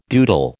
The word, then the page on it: doodle: American English